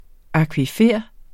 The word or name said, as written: akvifer